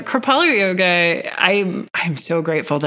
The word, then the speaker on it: Kripalu: So I teach something called Kripalu yoga and I always make this really lame joke about it because Kripalu is spelled K R I P A L U and so many people when they look at it they're like, cripple you? like, cripple you yoga.